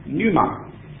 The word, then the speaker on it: pneuma: And in Greek, the word for wind, or at least one word for wind, is pneuma, although it's spelled with a P. Like some, you say it just with the S, but it's got a P there.